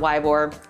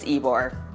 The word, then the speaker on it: Ybor: A lot of people will pronounce it Ybor, but it's not Ybor, it's Ybor.